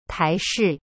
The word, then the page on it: 台式: tai shi